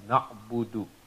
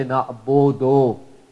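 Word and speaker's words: na'abudu (نعبد): but that's the appropriate original Arabic pronunciation is na'abudu. Because the sound is the same as waw, and the sound of waw is oo, it's not o. So you don't say na'abodo, you say na'abudu, na'abudu.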